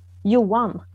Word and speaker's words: Johan: His name is spelled J-O-H-A-N, correct? – Yes. – And then how do you pronounce it? – Yuan. – Okay, so I would have said Johan, because, you know. Yeah. So Yuan.